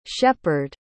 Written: shephard

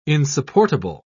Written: insupportable

